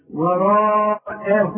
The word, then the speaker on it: وراءه: لا هنقول وراءه عندي واو صغير بعد الضمير دي علشان تمد هذا الحرف حركتين وراءه